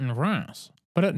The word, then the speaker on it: Reims: The city of Reims might sound confusing to you, but that's because Reims is one of the weirder bits of French pronunciation out there. It's spelled R-E-I-M-S, and as an American, I had always pronounced it Rhymes until I learned better.